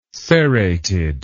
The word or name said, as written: serrated